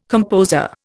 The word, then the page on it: composer: Indian